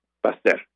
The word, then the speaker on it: Pasteur: el colegio francés Pasteur, Pasteur, pero se pronuncia Pasteur.